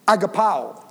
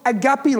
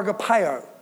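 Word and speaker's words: agapao (ἀγαπάω): That word love, agapao, that's how it's pronounced. It's not agape love, it's slightly different. It's agapao or agapeo.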